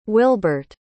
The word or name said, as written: wilbert